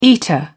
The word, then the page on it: eater: British English